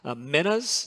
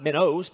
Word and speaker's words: minas: Let's talk about the pronunciation of that word real quickly. I have heard three acceptable English pronunciations of that word that I'm calling minas here. So you may have heard it called minas. That's fine. Minas. It's fine. Not minnows, but minas.